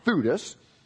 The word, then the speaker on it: Theudas: Some time ago, Theudas, also correctly pronounced Theudas, Theudas appeared claiming to be somebody, and about 400 men rallied to him.